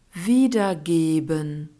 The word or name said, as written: wiedergeben